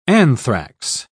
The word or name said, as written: anthrax